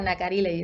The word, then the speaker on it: Nacarile: And forgive me if I say it wrong, Nacarile, is that how you say it? Or say the name for us and tell us more about it. – Yeah, Nacarile, you said it perfectly.